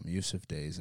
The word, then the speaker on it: Yussef Dayes: I know it's by Yussef Dayes and Tom-ish.